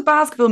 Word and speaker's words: Basciville: What does Basciville mean, by the way? And are we saying it right? – Yeah, absolutely. Iron fair play. You're not calling us Basciville or basketball or yeah. We've had it all.